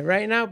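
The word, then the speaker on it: Rai Nao: Se llama Rai, R-A-I, Now. N-A-O. – N-A-O. – Sí, no es, no es Right Now escrito como Right Now. – Se pronuncia exacto, así. – Es Right Now, pero no Right Now.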